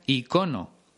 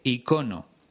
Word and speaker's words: icono: Por cierto, en España casi todo el mundo pronuncia icono, aunque también están admitidas las dos formas, ícono e icono.